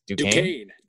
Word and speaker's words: Duquesne: And then your Hawaii game is, I can never pronounce this, Duquesne.